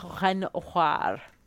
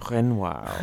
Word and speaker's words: Renoir: Yeah, wait, can you pronounce that name for us Frenchly? – Renoir. – Renoir. – Wow. – Renoir. – I went to You can say the R at the end because it is one of the four letters in the word careful. C, R, F, and L can be spoken as a consonant sound at the end of a word in French.